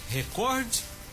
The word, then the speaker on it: recorde: A forma correta de escrita da palavra é recorde, com E no final, sem acentuação gráfica e com acentuação tônica na sílaba cor, sendo uma palavra paroxítona. Recorde. Corde. Então, o cor tem um som mais forte,